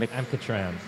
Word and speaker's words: Mkhitaryan: Mkhitaryan.